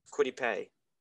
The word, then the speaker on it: Kwity Paye: Sorry if I mispronounced Kwity Paye.